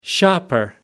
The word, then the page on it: shopper: American English